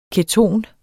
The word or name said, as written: keton